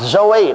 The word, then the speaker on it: Zoe: It is pronounced Zoe. Now I'm not a Greek scholar, but the professor of Greek in Baylor University, you know, in Waco, Texas, Baptist Seminary, told me that that's the way you pronounce it, is Zoe.